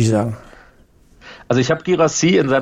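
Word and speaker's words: Guirassy: Girassi, haben wir richtig ausgesprochen? – Das klang sehr gut.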